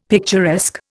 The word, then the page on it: picturesque: Indian